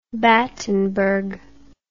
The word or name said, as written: battenburg